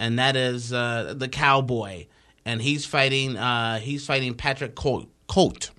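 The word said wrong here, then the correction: Côté: Oh, wow, said that name wrong. – Patrick Cote.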